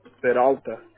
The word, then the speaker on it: Peralta: Peralta. – Okay, can you spell that for me? – P-E-R-A-L-T-A.